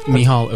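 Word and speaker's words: Mícheál: And, well, just before that, we heard from Kevin Burke and Michael Michal O'Donnell. – Michal O'Donnell. Okay. Michael. It's spelled Michael, folks.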